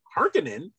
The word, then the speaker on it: Harkonnen: In this movie, they're the Harkonnens, and I'm like, that's the wrong emphasis. You're putting the emphasis on the wrong part of the word.